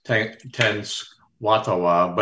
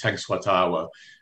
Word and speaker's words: Tenskwatawa: Occasionally, I've heard it pronounced Tenskwatawa, but it's Tenskwatawa.